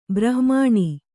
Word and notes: ಬ್ರಾಹ್ಮಣ: kannada; brāhmaṇa

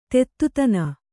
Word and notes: ದೂತತನ: Kannada

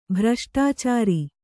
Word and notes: ಭ್ರಷ್ಟಾಚಾರಿ: bhraṣṭācāri

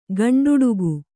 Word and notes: ಗಂಡುಡಿಗೆ: gaṇḍuḍige

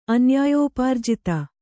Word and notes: ಅನ್ಯಾಯೋಪಾರ್ಜಿತ: Kannada

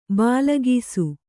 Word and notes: ಭಾಳಾಕ್ಷ: bhāḷākṣa

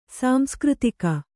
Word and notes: ಸಾಂಸ್ಕøತಿಕ: Kannada

